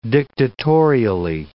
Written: dictatorially

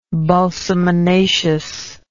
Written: balsaminaceous

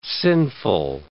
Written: sinful

